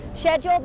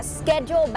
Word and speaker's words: schedule: ออกเสียงว่า schedule หรอออกได้นะคะว่า schedule หรือว่าจะเป็น schedule ก็ได้ค่ะ schedule แบบอังกฤษนะคะ schedule แบบอเมริกัน